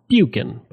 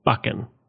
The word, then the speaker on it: Buchan: Now you think his name would be pronounced Buchan, but no, it's Buchan.